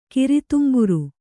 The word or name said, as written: ಕಿರಿತುಂಬುರು